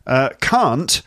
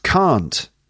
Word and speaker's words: can't: Can't, I think it's always pronounced can't, okay? Like, I can't, or I can't go, I can't swim, I can't. It sounds like car, drive a car, can't.